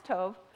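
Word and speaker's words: Tov (טוב): It's T-A-W-B on your handout. It's pronounced Tov.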